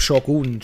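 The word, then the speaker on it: Shōgun: Shogun. Sagt man Richtig. das so? Spricht das, man das so aus? Ich glaube, Shogun spricht man das aus, ja.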